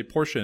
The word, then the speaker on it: Porsche: And the guy was like, if you can't say Porsche in two syllables, you're not allowed to own one.